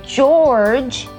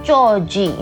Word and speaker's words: George: 还有就是名字，很多人好像把一些名字都念得不漂亮，像是乔治的英文是 George, 不是 Georgie, OK?